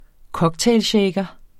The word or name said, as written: cocktailshaker